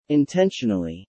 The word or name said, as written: intentionally